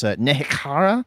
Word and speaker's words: Nehekhara: So in this version of the kind of world they're working with, it's Nehekhara. I can't get the guttural right, but my understanding is that's how it should be pronounced, is the KH has a fun little to it.